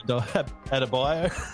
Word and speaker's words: Adebayo: Adebayo.